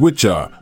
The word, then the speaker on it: wicca: The term, which originates from the Old English word Wicca, pronounced as Witcha, which means wise one.